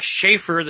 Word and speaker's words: Schaefer: You know, a lot of people might think with a name like Schaefer that maybe I was Ross Schaefer's little brother.